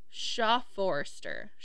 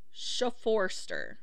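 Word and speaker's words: Schoepfoerster: They took it to the station, and then Officer Arthur Shelley and Captain Schaffermeister. Wow, I butchered that name. It's Captain Schafforster. Schafforster.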